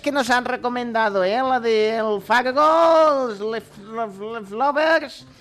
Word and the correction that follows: Leftovers: Sí, que lo he dicho mal yo. – Ah, sí. Ah, es mal. No es, es no, que no, veo bien. – Ah. – es, Leftovers.